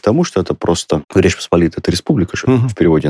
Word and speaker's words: Посполитая: Значит, Речь Посполитая, что есть республика, можно произносить двояко. – Угу. – Классическая это Речь Посполитая. – Да, и большинство поляков, как говорят, я польский не знаю, но мне это полонисты рассказывали, так и говорят.